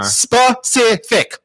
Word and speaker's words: specific: I know how to say it because it's one thing that pisses me off when people are like, Pacific. Man, you need to be more Pacific, yeah. Like more oceanic. I don't get it. – Yeah, I hate that. And I hate specific. Three syllables.